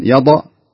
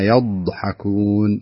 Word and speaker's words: يضحكون: أحسنت يا عبد الرحمن جزاك الله خير لكن لابد ننتبه لمسألة نطق حرف الضاد نحاول أن نمسكه خاصة أنه ساكن فلا نقول إذا هم منها يضحكون يضأ هذه فيها قلقلة المفروض تمسك الضاد كويس فتقول إذا هم منها يضحكون يضحكون